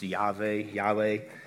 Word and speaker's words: Yahweh: Sometimes we'll say Jehovah. Most people think it was probably pronounced Yahweh.